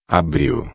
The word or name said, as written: abril